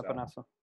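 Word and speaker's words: Epenesa: The Bills, you know, you called it, the defensive end from Iowa, A.J. Epinesa. Is that how you say it? – Yep, I believe so. – Epinesa.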